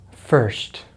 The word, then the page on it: first: American English